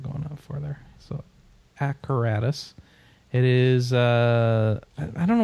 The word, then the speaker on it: Acaratus: Arcadus is spelled, or Acaratus rather, is spelled like apparatus but with a C. So A C A R A T U S.